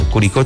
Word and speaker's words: Curicó: Saludito a la distancia para Carla Enríquez desde el sur de Chile, dicen que es de Curicó, así se pronuncia, Curicó, Chile.